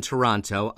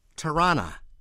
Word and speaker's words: Toronto: One is that the locals do not pronounce it Toronto, as we were all taught eons ago, enunciating the second T in the city's name. They say Tirana.